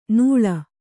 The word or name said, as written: ನೂಳ್